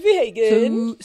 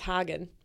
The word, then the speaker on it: Hagen: Actually, it's Hagen. I just saw them post a story the other day about how no one can pronounce her last name. – It's Hagen. – And it's Hagen.